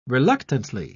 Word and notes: reluctantly: British English

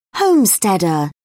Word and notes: homesteader: British English

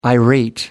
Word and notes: irate: American English

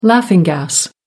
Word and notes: laughing gas: American English

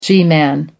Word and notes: g-man: American English

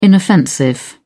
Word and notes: inoffensive: British English

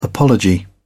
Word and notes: apology: British English

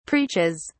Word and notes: preaches: British English

